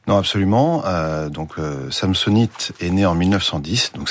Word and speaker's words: Samsonite: Vous le prononcez à la française, hein, Samsonite. – Oui, bon, je vais suivre votre prononciation.